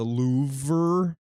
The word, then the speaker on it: Louvre: I very recently went to the Louvre. – Right. – Which is how it's properly pronounced.